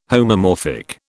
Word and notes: homomorphic: British English

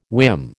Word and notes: whim: American English

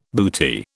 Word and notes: booty: British English

